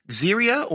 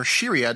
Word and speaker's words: Xeria: The Mark VI, who took the name Xeria or Shiria, depending on how you pronounce it, X-E-R-I-A-A, was hunted down by bounty hunters after being on the run and specialized in explosives.